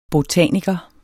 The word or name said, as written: botaniker